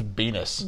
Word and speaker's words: Beanus: His last name's Beanus. – Beanus rhymes with penis. – It does. It rhymes.